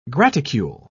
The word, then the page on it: graticule: British English